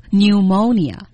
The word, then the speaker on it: pneumonia: 哎，大家注意肺炎这个单词啊，虽然平时我们用的少，但是你看它的读音很有意思，那个 P 打头的那个 P 呢，它是不发音的，直接读成 pneumonia。